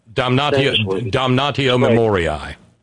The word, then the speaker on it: damnatio memoriae: is the Senate would pass a resolution that I'm guessing at the pronunciation, don't know Latin, damnatio memorare, where they would damnatio memoriae. – Yeah, okay, there you go.